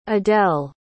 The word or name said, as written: adelle